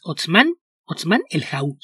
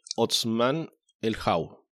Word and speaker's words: Otsmane-Elhaou: Y es Utsman Elhau, se pronuncia. – Ok, Hassan Utsman Elhau.